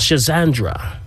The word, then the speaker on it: Schisandra: So with that, I want to talk about a specific herb today, and this one is called Schizandra. It's spelled S-C-H-I-S-A-N-D-R-A.